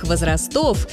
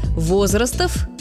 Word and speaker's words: возрастов: Во всех формах существительного «возраст» ударение ставится на первом слоге. Возрасты, возрастов, возрастом, о возрастах. И только так.